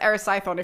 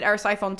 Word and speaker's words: Erisichthon: And as far as I'm aware, it's Erisichthon. If you're Greek, then please correct me in the comments below. But my teachers have always said Erisichthon to me.